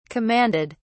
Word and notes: commanded: British English

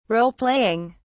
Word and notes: roleplaying: British English

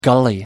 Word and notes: gully: American English

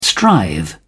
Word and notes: strive: British English

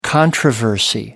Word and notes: controversy: American English

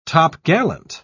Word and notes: topgallant: British English

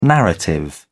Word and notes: narrative: British English

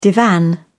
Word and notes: divan: British English

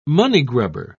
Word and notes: moneygrubber: British English

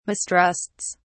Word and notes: mistrusts: British English

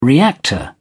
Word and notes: reactor: British English

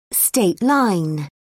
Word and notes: state line: British English

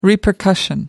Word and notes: repercussion: American English

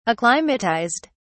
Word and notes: acclimatised: British English

